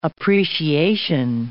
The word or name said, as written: appreciation